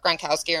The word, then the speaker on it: Gronkowski: you know, Gronkowski rhymes with Romanowski.